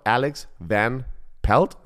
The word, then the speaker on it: Alex Van Pelt: Offense-Koordinator Alex Van Pelt, spricht man, spricht man das so aus? – Ja, ehemaliger der, Quarterback.